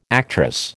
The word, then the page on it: actress: American English